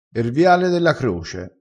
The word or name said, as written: viale